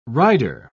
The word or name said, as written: rider